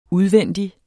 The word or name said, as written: udvendig